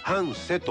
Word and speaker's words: 繁盛: これ商売繁盛の繁盛と書いて半瀬と読むんですけどもね、ここがですね、半瀬小学校が閉校しましてね、でその地域の将来をこれから考えていこうということで、モア半瀬、もっと半瀬というのが立ち上がりましてね。